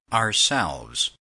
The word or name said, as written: ourselves